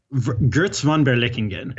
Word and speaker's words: Götz von Berlichingen: Götz von Berlichingen. How do I say that? – Götz von Berlichingen.